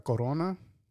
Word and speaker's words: Corona: You gotta roll the R, man. You gotta do it. – No, that's dude. That's how Corona.